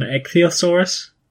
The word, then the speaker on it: ichthyosaurus: You're going to have to help me with the pronunciations here, but I think it's an ichthyosaurus, an ichthyosaur and a plesiosaur. Is that correct? Yeah. Spot on.